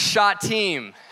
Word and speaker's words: Shittim: First off, that word is pronounced Shittim. Learn that this week. I've been saying it wrong my whole life.